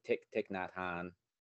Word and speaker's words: Thich Nhat Hanh: but with Thich Nhat Hanh.